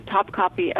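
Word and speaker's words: Topkapı: So it is a real place, but we say it like Top Copy.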